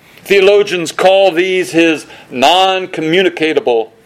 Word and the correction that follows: non-communicable: that's not how you pronounce it, non-communicable attributes.